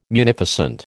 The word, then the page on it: munificent: American English